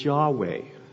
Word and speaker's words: Yahweh: And a while back I did a little study on the names of God, and this personal name is the name that we believe is pronounced as Yahweh. We don't really know, because ancient Hebrew didn't have vowels.